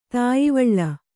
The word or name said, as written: ತಾಯಿವಳ್ಳ